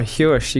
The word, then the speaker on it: Huish: No, Huish. You got it. – Good. – Nailed it. – Good. – Not many people get it. – No. – What are the other kind of pronunciations of it? – Huish. – Huish. Swish. – Swish. – That should be a nickname. – Some people just go hush.